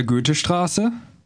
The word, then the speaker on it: Goethestraße: In der Goethestraße? Goethestraße? – The letter combination OE is pronounced Ö.